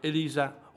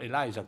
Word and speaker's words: Eliza: E lei è Elisa o Eliza, qualcuno la pronuncia così, comunque Gil Kyson.